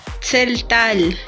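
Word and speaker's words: Tzeltal: Setzal. – Ni siquiera sabes pronunciarlo, güey. – Zeltal.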